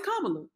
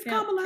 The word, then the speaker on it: Kamala: Because you know how Kamala, that's how you say her name, right? – Kamala. – No, it's Kamala. It's Kamala Harris. Kamala.